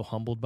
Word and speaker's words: Humble: Played at Humble High School. Don't pronounce the H. It's silent.